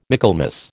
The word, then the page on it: Michaelmas: American English